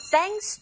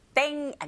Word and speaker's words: thanks: Now try the second listening point, that is, thanks to trustworthy partners. To를 제외한 나머지 모두 다 내용어입니다. 자, thanks. 이제 th 발음은 제대로 잘 표현하실 수 있겠죠? 구사하실 수 있겠죠? 땡 아니고 th 긁어주셔야 돼요. Thanks to.